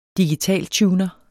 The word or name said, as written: digitaltuner